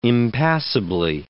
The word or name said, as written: impassably